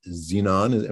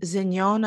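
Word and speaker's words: Zenón: There was, it was interesting that we dealt with, I don't know how to pronounce his last name, Miguel Zignon, am I saying that right? – Zignon, I believe, Zignon.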